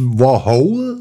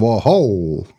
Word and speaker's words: Warhol: Nicht Warhol, sondern Warhol. Sei ich ja ein tschechischer Name, ja.